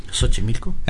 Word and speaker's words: Xochimilco: Xochimilco. – ¿Qué hace, güey? Xochimilco.